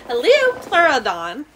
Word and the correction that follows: Liopleurodon: It's pronounced Liopleurodon.